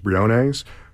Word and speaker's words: Briones: I think what's great about Issa Briones, I think it's pronounced Briones, who plays Santos, is that, yes, you're right.